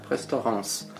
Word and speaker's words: Restaurants: Значит, в ресторане ты не читается. – Ресторан. Да? Носовое. – Носовое «на» в конце. Restaurants.